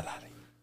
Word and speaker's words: Ferrari: A Ferrari. It's an Italian car. It's not a German car. Ferrari.